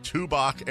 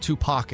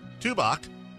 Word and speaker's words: Tubac: The film, the movie was filmed in the tiny town of, I hope I'm pronouncing this right, and if I'm not, it should be pronounced this way, Tubac, Arizona. It's T-U-B-A-C, and if it's Tubac, I don't care. I'm pronouncing it Tubac. – Also not Tupac, Arizona.